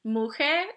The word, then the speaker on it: muxe: Or like in Mexico you have, I think it's, I always forget what it's, how it's pronounced, but I think it's the Muje, which are, it's spelled M-U-X-E.